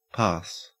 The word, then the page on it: parse: Australian English